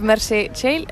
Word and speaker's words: Mercè Tell: Today I'm with Mercè Tell. Is that how you pronounce it? Yeah, perfect.